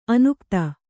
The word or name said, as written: ಅನುಗತಿ